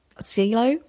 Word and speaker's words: Coelho: Oh, sorry, did I say that book was The Alchemist by Paul, I think it's pronounced Coelho, Paulo Coelho. So P-A-U-L-O, and then his last name is C-O-E-L-H-O.